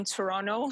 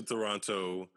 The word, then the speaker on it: Toronto: And you know that because I don't pronounce either of the T's in Toronto.